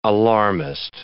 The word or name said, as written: alarmist